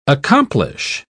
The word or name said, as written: accomplish